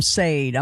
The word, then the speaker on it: Sade: Sade, man. Yeah, exactly. Yeah, I said it wrong on live on radio. I called him Sade. I'm like, oh, I never lived that one down. So, yeah, Sade.